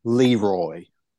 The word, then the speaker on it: Leroy: I think we ought to use the Southern pronunciation of the doctor guy that threw him down in that hole. That would be Dr. Leroy. – Yes. Yeah. – Emphasis on the first rather than the second syllable.